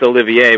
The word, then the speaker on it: Olivier: Well, Oliver, you know, we're talking about good nicknames. I think it's Olivier, by the way.